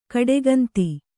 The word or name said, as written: ಕಡುಗಂದಿ